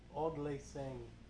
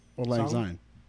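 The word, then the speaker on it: Auld Lang Syne: Odd Lang Sang, right? – I guess so. – Odd Lang Sang? – Old Lang Syne.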